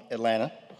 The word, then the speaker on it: Atlanta: Local from Atlanta. Yes, that second T is silent.